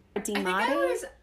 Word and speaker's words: Demati: So, also Lydia is a Demati. How do you say that? – Demati. – Thank you. I was putting the emphasis on the wrong syllable.